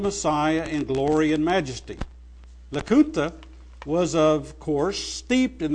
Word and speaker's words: Lacunza: While they were expelled from Chile, Emmanuel Lacunza, it's spelled L-A-C-U-N-Z-A, like Lacunza, but it's pronounced Lacunza,